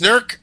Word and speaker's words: Newark: I've got the pronunciation right. I was actually tutored in that. Newark, Ohio, instead of Newark, Ohio. It's Newark, Ohio.